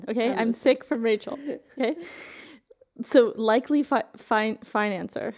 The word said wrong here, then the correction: financier: How do you say that? – Financier.